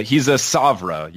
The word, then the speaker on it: PSOVRA: P-S-O-V-R-A. So he's a Savra, you know, silent P, like pterodactyl.